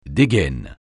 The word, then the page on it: dégaine: French